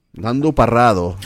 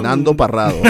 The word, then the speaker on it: Nando Parrado: Nando Parrado. That's how you pronounce his name. Nando Parrado.